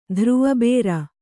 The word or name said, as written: ಧ್ರುವಬೇರ